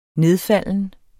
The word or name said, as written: nedfalden